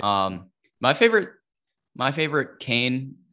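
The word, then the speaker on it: kāne: and it would say I think it's pronounced Kane in uh, in the Hawaiian language.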